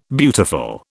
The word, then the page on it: beautiful: British English